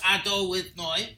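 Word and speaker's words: Adonai: So, Amen, 91, is equal to Hashem, which is pronounced Ado with Noi.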